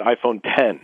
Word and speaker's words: iPhone X: iPhone X, they want us to pronounce it iPhone 10, even though it's spelled iPhone X.